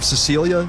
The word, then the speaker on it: Cecilia: And a big shout-out to Joe in the chat, who on the Sam Cam feed has at least illuminated me a soft C first name of Cecilia, which he also astutely points out, not many ball players with that name.